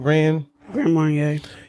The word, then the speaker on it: Grand Marnier: Grand Marnier.